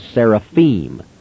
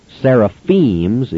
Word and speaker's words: seraphim: The singular is seraph. The plural is seraphim. Seraphims is very much like talking about deers or mooses. It simply is not the way you spell it or pronounce it.